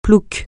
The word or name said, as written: plouc